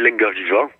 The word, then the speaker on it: Linga Viva: En français, on le lirait Linga Viva, et en occitan, on le lit Lingobibo.